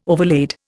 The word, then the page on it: overlaid: Indian